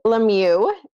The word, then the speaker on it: Lemieux: I'm Ashley Lemieux. I'll pronounce my last name for all of you because when I was getting married to my husband, I literally didn't know how to say it.